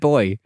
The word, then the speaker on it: Bowie: When I said bow there, I was thinking, how do you pronounce David Bowie? Because I was going to get it right. I was like, he's never bow, he's Bowie.